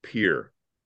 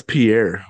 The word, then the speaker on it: Pierre: Capital of South Dakota is pronounced Pier. – And how's it spell it for everybody? – E-I-E-R-R-E. – Mm. – Here. – That's Pierre, brother.